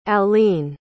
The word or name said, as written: allene